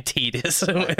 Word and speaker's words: Tidus: You're being Tidus with your pronunciation. – It's Tidus, like Tidies.